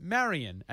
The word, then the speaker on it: Marion: So I said to them, Marion, as in Maid Marion.